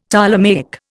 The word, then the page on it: Ptolemaic: Indian